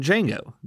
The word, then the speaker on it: Django: The movie Django Unchained is about a young slave named Django, the D is silent, and his partner in crime, a German bounty hunter named Dr. King Schultz.